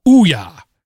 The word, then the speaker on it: Ouya: has told me that the proper pronunciation of the prior game console is Ouya, as in the sound you might make when playing a game. Ouya! So there you go.